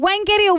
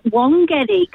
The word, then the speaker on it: Wangetti: Catherine in Caravonica, Wangetti or Wongetti? How do you pronounce it? – I always pronounced it Wongetti because when we were young, we just called it Wongetti.